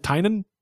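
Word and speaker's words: Tynion: I've always pronounced it Tinian, James Tinian the Fourth. It seemed like they pronounced it Tynan.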